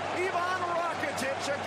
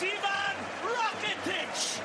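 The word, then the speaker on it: Ivan Rakitić: Ivan Rakitic against his old team. He won't celebrate, but the new Kamp will. – Think you got the name wrong, Phil. It's Ivan Rakitic.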